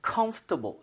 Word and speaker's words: comfortable: 저번에도 말씀드렸지만 요 단어로 할 때는 comfortable이라고 하는 사람도 있는데요. comfortable 해서 OR 발음을 거의 안 하는 사람들이 굉장히 많아요.